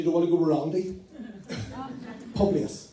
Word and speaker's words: Publius: Publius. – Publius, okay, I'm going with what you said, okay.